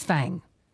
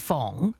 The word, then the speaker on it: Fang: Their name was Fong Ek Wok, and everybody pronounced it as Fang. So it was written F-A-N-G, and the pronunciation is Fong, but because everybody called her Fang, she didn't like it.